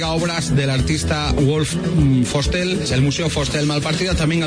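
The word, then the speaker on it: Vostell: La fonética alemana marca que la V se pronuncia como F. – Vale. – y es Museo Fostel.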